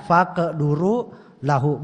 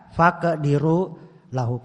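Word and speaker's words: faqduru lahu (فاقدروا له): Bacanya faqduru lahu, bukan dibaca faqdiru lahu.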